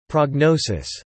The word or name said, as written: prognosis